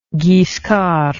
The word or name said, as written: Guiscard